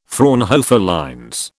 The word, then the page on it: Fraunhofer lines: British English